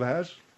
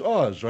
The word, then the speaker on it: Oz: Wizard of Ass? – It's not Wizard of Ass, you know that. – No, I know that, but I didn't know how to pronounce it. It's Oz, right? – Yes.